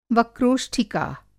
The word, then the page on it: वक्रोष्ठीका: Hindi